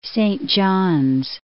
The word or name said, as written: Saint Johns